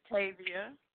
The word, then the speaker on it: Tavia: It was a part of my passion, just meeting new people, connecting with people, learning people's personalities, and, like, Tavia, am I saying that right, or Tavia? I'm sorry if I pronounced it wrong, but it's Tavia.